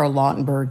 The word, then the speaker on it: Lautenberg: Well, not that recent now, but Lynn, what happened with the so-called Lautenberg, I think it's pronounced, amendments in 2016? – Well, in 2016, on June 22nd, then President Obama signed the Frank R. Lautenberg Chemical Safety for the 21st Century Act.